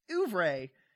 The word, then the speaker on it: oeuvre: Oeuvre? I still don't know how to pronounce it. – And I think it's oeuvre. – Oeuf? Is it French? It'd be oeuf if it's French. – Well, it's Oeuvre.